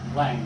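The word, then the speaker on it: Laing: I'm going to start the ball rolling, pretty devastating, let's say, with the great Scottish psychiatrist Arding Lang, or Lege if you follow the dictionary pronunciation, who wrote, "We respect the voyager, the explorer, the climber, the spaceman.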